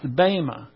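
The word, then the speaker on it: bema (βῆμα): It's not bema in the Greek. Actually, it's got an eta instead of epsilon, pronounced bema.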